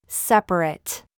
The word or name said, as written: DRESS